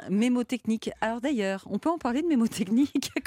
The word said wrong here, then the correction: mnémotechnique: Comment ça s'écrit C'est mnémotechnique. Mnémo, d'accord. Mm.